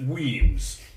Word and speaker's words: Wemyss: Weems. It's easier to say than to read. You pronounce it Weems. It's the Scots word for caves.